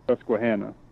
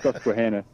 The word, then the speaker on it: Susquehanna: So that's you, how you pronounce, you pronounce that. It, yeah, you pronounce it kind of funny, which made me yeah. chuckle. But yeah. yeah. It's, well, it's, see, it's Susquehanna.